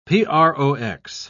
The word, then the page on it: prox.: British English